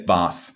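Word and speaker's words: Waas: It's W-A-A-S, but it's pronounced Voss. Is that right? – So, yeah. So in Germany, you would say Voss. – Yeah. – And so I'm wondering if, you know, the skier Voss, V-O-S-S, if that used to be my name and they just changed it so that Americans would pronounce it the right way.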